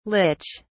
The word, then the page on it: lich: British English